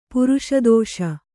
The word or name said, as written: ಪುರುಷದೋಷ